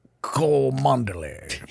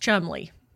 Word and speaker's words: Cholmondeley: So apparently how you pronounce it, it's Sarah Rose, spelled Cholmondeley. – Cholmondeley. – Marchioness of Cholmondeley. Apparently, as the Brits love to do with words like Leicester or Worcester sauce. – Or Lieutenant. – They like to, yes, they like to pretend 90% of the letters aren't there. According to British pronunciation, that is pronounced Chumley. – Chumley? – Chumley.